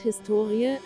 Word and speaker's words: Historie: Das Wort Historie, HS2IE, die Betonung liegt auf dem O, bezeichnet bis in das 18.